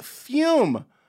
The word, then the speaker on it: Fume: Fume. That's spelled F-U-M.